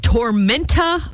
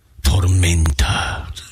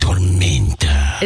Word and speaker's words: Tormenta: They say that this specific roller coaster is called the Tormenta Rampaging Run. – You didn't say it right. Tormenta. You gotta say it like that. Tormenta.